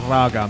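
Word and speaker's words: ragga: This reggae metal shit that they do. It's awesome. – It's pronounced raga metal.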